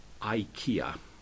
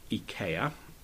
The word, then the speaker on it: IKEA: Nearly all English people say IKEA, but I know that in Sweden people say IKEA, and since IKEA is a Swedish company, that is the way I pronounce their name.